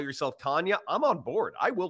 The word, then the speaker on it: Canha: Mark Canha was on ESPN, mic'd up, and he actually said, yeah, the actual pronunciation is Canha.